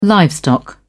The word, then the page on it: livestock: British English